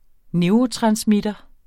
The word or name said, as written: neurotransmittere